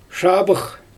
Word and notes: ʃabɯx: Xyzyl

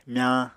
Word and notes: myañ: Apatani

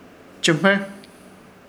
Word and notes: Chɨhmer: Ishir Chamacoco

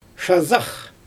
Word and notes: ʃazax: Xyzyl

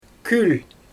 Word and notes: kül: Xyzyl

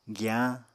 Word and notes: giyañ: Apatani

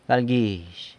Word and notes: galguizh: sanlucasquiavini

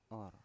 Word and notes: or: Biak

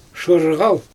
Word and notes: ʃɯɣal: Xyzyl

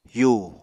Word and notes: yu: apatani

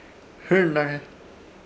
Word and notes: Hɨrnãrhe: Ishir; Chamacoco

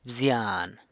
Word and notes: bzyan: San Lucas Quiaviní

